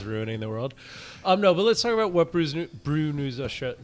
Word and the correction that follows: Brews News: Did I say it right? Brew News Australia. – Brews News. Yep.